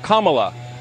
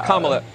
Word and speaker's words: Kamala: Her name is pronounced comma, like the punctuation mark. Look, Kamala.